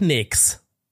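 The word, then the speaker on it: Knicks: How to pronounce it. New York nix. Warte, warte. Ja, doch, nix. Ja, okay, ist richtig.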